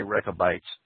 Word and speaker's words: Rechabites: Jeremiah chapter 35, what we see, what seems like Yahweh's commendation of the Rechabites, or Rechabites, or Rechabites, or however you want to pronounce it, I'll say Rechabites.